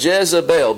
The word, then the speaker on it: Jezebel: If you think about Jezebel, B-E-L, if you do the alternate pronunciation, Jezebel, you hear Baal in there?